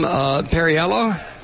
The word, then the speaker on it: Perriello: Joining us now is Tom Perriello.